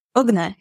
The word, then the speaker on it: Ugnė: Ugnė.